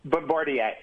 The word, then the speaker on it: Bombardier: But in any event, she, and it's, how do you say it? Bombardier. – Bombardier.